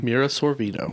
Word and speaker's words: Mira Sorvino: Mira Sorvino.